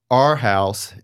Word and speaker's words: Arhaus: It's pronounced like A-R-E, Arhaus, and not with an O.